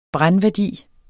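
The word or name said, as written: brændværdi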